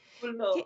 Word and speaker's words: Trullo: Trullo, which is my great joy.